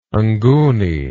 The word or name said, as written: nguni